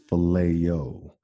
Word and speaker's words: phileo: It's not filet of fish. It's phileo, which is a friendship, brotherly type love.